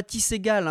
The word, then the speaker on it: Ty Segall: On prononce Ty, non pas Ty Segall, hein. C'est pas du Tergal non plus.